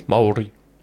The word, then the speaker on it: Māori: No, it's not Māori. – I don't fucking... – No, it's Māori. I can't roll my R's properly. – Māori.